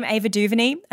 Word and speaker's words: Ava DuVernay: Yeah, Ava DuVernay, who did, or yeah, how we pronounce her name, Ava DuVernay. I'm going to go with that. – I think so. I think you got it right.